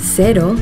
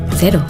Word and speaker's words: cero: In Yucatan, the local Mexican surfers pronounce cero, cero. During the warm summer evenings in bars around the coast of Nayarit, you'll hear them say, cero. In New Zealand, zero alcohol Corona, cero, is pronounced cero.